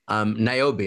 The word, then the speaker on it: Niobe: Niobe, okay, yay, I got the name right. Okay, I spelled it, but I couldn't pronounce it, right?